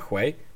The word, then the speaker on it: Huawei: E, pogłoski o nowych Nexusach, jeden ma robić Huawei, tak się to wymawia, Huawei.